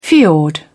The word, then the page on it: fiord: British English